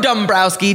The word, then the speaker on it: Dombrowski: Joe Dombrowski.